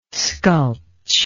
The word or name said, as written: sculch